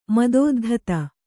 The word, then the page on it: ಮದೋದ್ಧತ: madōddhata